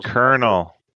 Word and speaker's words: Colonel: Oh, it's C-U-R, Colonel. – K-E-R-N-A-L. – Let me read this card. Colonel? – Colonel, it's spelled like popcorn.